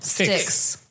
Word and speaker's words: Styx: Six.